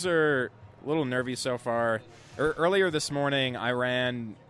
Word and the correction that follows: Iran: Iran, sorry, I pronounced it wrong, beat Wales with a resounding 2-0 win, scoring two goals in after the 90th minute in 9 minutes of stoppage time.